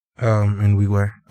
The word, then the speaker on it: Nwigwe: Is it Nwigwe or Nwigwe? – Nwigwe.